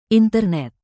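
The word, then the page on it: internet: indonesian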